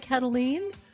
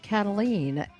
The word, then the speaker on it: Kataline: I'm Karen Cataline, commentator, columnist, all-around rabble-rouser. I maintain a pretty active Twitter page at Karen Cataline. That's spelled K-A-R-E-N. Yes, I'm a Karen and not afraid to admit it. Cataline, K-A-T-A-L-I-N-E.